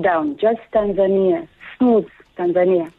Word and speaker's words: Tanzania: You pronounce the word Tanzania. It's actually neither. Kiswahili is a very straightforward language. The way you write it, it's the way you pronounce it.